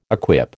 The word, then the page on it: equip: American English